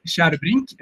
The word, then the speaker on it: Kärrbrink: And mine is pronounced Kärrbrink, and yours is pronounced Tjärnström.